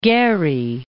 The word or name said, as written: Gehry